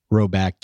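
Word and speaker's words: Rhoback: Roback, look, we've talked about Roback before. Got a little bit of Baker Mayfield in him. – Yep. – He was spotted wearing some of that Roback gear.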